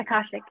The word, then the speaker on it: Akashic: Okay, and so could you explain what hypnotherapy and Akashic Records? Did I say it right? – Akashic, yeah.